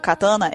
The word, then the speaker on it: katana: Como vocês perceberam, a pronúncia da, da palavra katana ou katana é katana. Então, to todas têm acento, né? Todas as sílabas possuem acento para pronunciar.